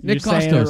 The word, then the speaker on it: Nick Kostos: Nick Kostos. – Did I say it right? – Nick Kostos. – Right.